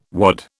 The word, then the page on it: wad: British English